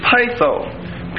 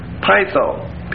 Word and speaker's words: peitho: The word assure is pytho, P-E-I-T-H-O, pytho, P-E-I-T-H-O, it's a long O, means to persuade.